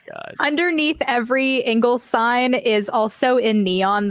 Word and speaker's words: Ingles: When I moved to Georgia, that was the first time I ever came across an Inglis. Is that how you say it? – No. – Ingles.